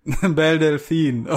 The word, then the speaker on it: Belle Delphine: Machst du jetzt so, äh, wie heißt die, wie heißt die Belle Daphne? Sowas in etwa? – Belle Delphine.